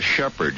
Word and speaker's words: Shepherd: Shepherd, just the way the word shepherd is spelled. – H-E-P-A-R-D? – No, S-H-E-P-H-E-R-D.